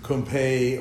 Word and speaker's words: Kumpe: K-U-M-P-E, but it comes out Crump or Kumpe or Kump or anything but Kumpe.